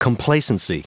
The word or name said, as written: complacency